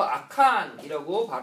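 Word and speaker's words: 아칸이라고 (עכן): 아간이라는 본래 자신의 이름의 뜻은 뭐냐면 히브리어 아칸이라고 발음을 하는데요. 바로 골치 아픈 이런 뜻이에요.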